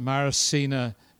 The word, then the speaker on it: Mairishine: So I'm going to start at the far end, and we've got Mairishine is the proper pronunciation, Scots Gaelic, Stanfield, and Mairishine is the minister of First Bangor Presbyterian Church.